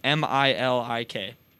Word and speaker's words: Milik: It's spelled ARKADIUSZ, and then his last name is MILIK.